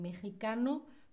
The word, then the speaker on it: mexicano: Una persona de México sería un mexicano, mexicana, sí es una X, pero no se dice mexicano, mexicana porque eso viene de antiguamente cuando en español la X es una J, ¿vale? Se pronuncia como una J, ¿sí?